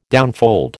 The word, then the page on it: downfold: American English